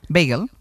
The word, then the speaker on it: bagel: Ah, claro, beagle es un perro, ¿no? – Sí. Un bagel es lo que se come con queso Filadelfia, por ejemplo. – Bueno, eso es. – Sí, eso es un bagel.